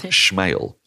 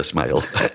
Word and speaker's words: Smale: She told us that it was pronounced Schmale, as if Sean Connery was doing it. Sophia Schmale. – Right. – Turns out it isn't actually. It's just the way it's spelt. Sophia Smale. She was a real revelation.